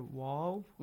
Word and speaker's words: و: First is the letter waw. – Waw. Waw has to be pronounced correctly. Not a v sound we don't have. Like the letter V in English. We don't have that in Arabic.